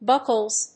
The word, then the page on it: buckles: ˈbʌkʌlz